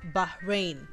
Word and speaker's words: Bahrain: Bahrain. Bahrain. Ooh, look at me doing the H thing.